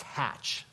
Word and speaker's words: Hatch: It's Hatch. I said it wrong. H-A-T-C-H. Hatch Show Print.